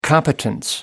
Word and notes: competence: American English

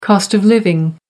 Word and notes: cost of living: American English

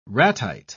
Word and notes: ratite: British English

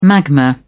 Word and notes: magma: British English

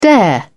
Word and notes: dare: British English

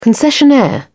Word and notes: concessionaire: British English

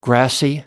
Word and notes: grassy: American English

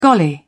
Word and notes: golly: British English

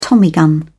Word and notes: tommy gun: British English

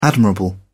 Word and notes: admirable: British English

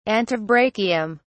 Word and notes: antebrachium: British English

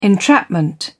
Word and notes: entrapment: British English

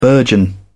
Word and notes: burgeon: British English

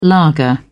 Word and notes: lager: British English

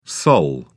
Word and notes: sol: British English

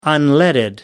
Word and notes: unleaded: American English

